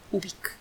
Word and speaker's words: Ubik: Ubik Ubic se escribe como suena, con U, con B grande, luego una I latina y luego una K. Ubic.